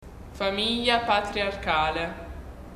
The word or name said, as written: patriarcale